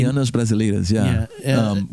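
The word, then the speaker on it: Bachianas Brasileiras: And it's Bacchinas Brasileiras. Is that how you say it? Number five, Funky. – Bahianas Brasileiras, which is a classical piece composed by Heitor Villa-Lobos, who's like the famous Brazilian composer.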